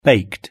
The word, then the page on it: baked: British English